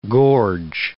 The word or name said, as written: gorge